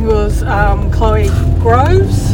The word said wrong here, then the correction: Greaves: Greaves, I believe it's pronounced.